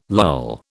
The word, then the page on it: lull: British English